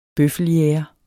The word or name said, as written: bøffeljæger